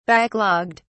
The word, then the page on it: backlogged: British English